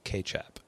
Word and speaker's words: kecap: And the Indonesian Malay word for the sauce was K-E-C-A-P, pronounced K-chap.